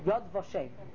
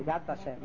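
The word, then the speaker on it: Yad Vashem (יד ושם): Yod Vashem. Right? Or Yad Vashem, right? The way it's pronounced today.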